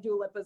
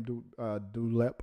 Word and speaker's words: Dua Lipa: Britney to some degree, and then Dua Lipa is a fun modern, but like she's a good performer. – It would most likely be how do you say her name? Dulep.